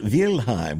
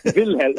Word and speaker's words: Wilhelm: He was born Warren Wilhelm, Warren Wilhelm Jr., son of Warren Wilhelm Sr. That would be pronounced Wilhelm, wouldn't it? – Wilhelm, yes.